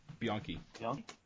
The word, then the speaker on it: Bianchi: Is that how you say it? – Simone Bianchi? – Bianchi. Mm-hmm.